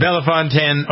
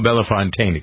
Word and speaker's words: Bellefontaine: All right, Jennifer is in Bellefontaine, Ohio. Bellefontaine, excuse me, I got to pronounce it correctly.